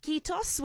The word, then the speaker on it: kiitos: kiitos, which is the word for thank you.